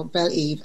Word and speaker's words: Beleve: All right, so, let me tell you what I thought of when I saw Belle Eve. And for our listeners, it's spelled B-E-L-E-V-E.